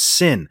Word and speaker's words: Syn: His name is Sin. Um, I did not know his name was Sin because it's spelled S Y N. So for a year, I called him S Y N.